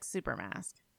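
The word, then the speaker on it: Xupermask: It is spelled with an X. Where is the X in the word Super Mask? I'll let you guess at home on this audio format. – Are you going to spell it out for us? – The X is at the front. It's Super Mask, but it's super. You say it like Super Mask.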